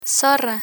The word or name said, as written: zorra